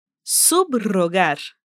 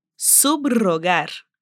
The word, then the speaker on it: subrogar: E quando o R está depois dos prefixos ab, sub e post. Nesses casos, vamos pronunciar os prefixos separados do R que vem em seguida. Por exemplo, abrogar, abrogar, subrogar, subrogar, postromântico, postromântico excelente, talvez você esteja pensando: como que eu vou lembrar de todos esses casos?